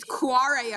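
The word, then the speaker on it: Kvarøy: It's Quarry Arctic. I didn't know how to pronounce it for a very long time.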